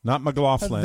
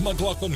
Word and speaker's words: McLaughlin: McLaughlin.